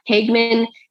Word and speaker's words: Hageman: However, like Hageman, is that how you pronounce it? Yeah, Hageman did win the primary for that.